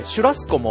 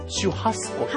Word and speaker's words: シュハスコ: じゃあもしかしてシュラスコも本当はシュハスコ。